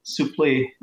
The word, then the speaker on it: Suplee: What is that, English or French? – French. – I pronounce it Suplee. – Suplee. Yeah. Suplee. And the French do another really weird version. I think they say Suplee. – I would say in French it should be Suplee.